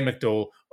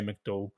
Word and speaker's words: McDowell: I say McDowell. If you're from Northern Ireland, you say McDowell. It's Graham McDowell to me, not Graham McDowell.